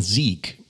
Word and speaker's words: Zeke: Also Zeke geschrieben, ausgesprochen Sieg.